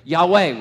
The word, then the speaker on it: Yahweh: YHWH. Yahweh, we pronounce it. But they never pronounced it.